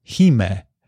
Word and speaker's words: hime: And then we had several people let us know that the proper Japanese pronunciation of the Heim cut is Hime. The Hime cut, because it is for a Japanese princess. So if you want to say it properly in the Japanese way, that would be the way to say it.